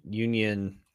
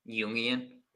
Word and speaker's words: Jungian: So this is where he starts to get into the union. I pronounce that correctly. – Jungian.